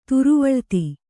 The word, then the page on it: ತುರುವಳ್ತಿ: Kannada